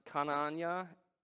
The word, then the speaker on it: Khananyah: Ananias comes from Hananiah, pronounced Kananya, K-H-A-N-A-N-Y-A-H, in the original manuscript.